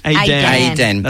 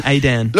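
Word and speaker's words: Aydan: Aiden. That's the one. Aiden.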